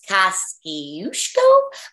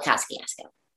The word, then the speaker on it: Kosciuszko: The Kosciuszko Bridge, that's, I think, is pronounced Kosciuszko, but I call it Kosciuszko. So you are allowed to call it whatever you want.